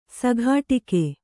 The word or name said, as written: ಸಿಕ್ಕಟ್ಟಿಗೆ